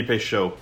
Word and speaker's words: Peychaud: Is that how you pronounce it? Do you know Peychaud? Peychaud? – It'd be Peychaud. – Peychaud. Is that real? Are you fucking with me? – No. Peychaud.